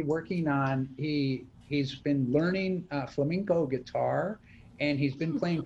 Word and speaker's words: flamenco: So as a 15-year-old, he's not on the road yet, but he is a flamenco dancer. And I always say it wrong. I say it like the bird, and I get yelled at. Flamenco.